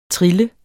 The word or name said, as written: trille